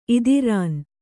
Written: ಇದಿರಾನ್

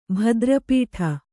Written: ಭದ್ರಪೀಠ

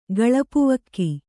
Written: ಗಳಪುವಕ್ಕಿ

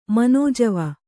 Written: ಮನೋಜವ

